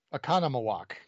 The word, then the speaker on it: Oconomowoc: And the cop goes, "You're about 6 miles outside of Okanomowoc. One second. Let's see if I can get this right. Okanomowoc Wisconsin. – Oconomowoc. That's how you say it.